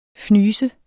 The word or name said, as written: fnys